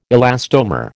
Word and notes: elastomer: American English

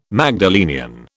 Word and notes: Magdalenian: British English

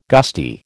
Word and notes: gusty: American English

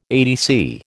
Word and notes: ATC: American English

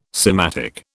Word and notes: sematic: British English

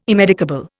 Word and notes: immedicable: Indian

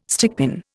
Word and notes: stickpin: Indian

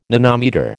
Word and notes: nanometre: American English

